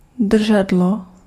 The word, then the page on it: poignée: /pwa.ɲe/